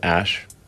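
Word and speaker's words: Æ: The AE is like... pronounced Ash.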